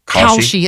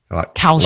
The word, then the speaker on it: Kalshi: Kalshi. It sounds a little bit like cow. – Kalshi. Like California.